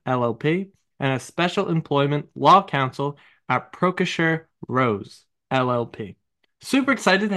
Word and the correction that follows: Proskauer: But I will correct you that it's Proskauer. And it's very hard to know how to pronounce it.